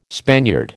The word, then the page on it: Spaniard: American English